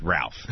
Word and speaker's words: Ralph: It's spelled Ralph, but when you pronounce it Rafe. I don't pronounce it Rafe. I pronounce it Ralph. Other people can be talked into pronouncing it Rafe.